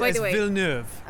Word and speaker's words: Villeneuve: What's his name? Denis Villeneuve. – Right. That's not how you say it. – It's Villeneuve. – Okay. – It's French. He's French.